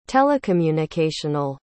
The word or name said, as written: telecommunicational